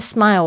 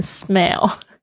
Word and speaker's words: smile: smile number seven 哦，这边 smile 我要特别讲一下，千万不要讲成 smell，因为有一些同学那个爱的发音发不好，就会变成 smell。